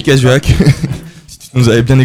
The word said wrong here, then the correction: Cahuzac: Reprononce-le pour voir. – J. Cahuzac. – Cahuzac. Oui. Tu avais dit Casuac.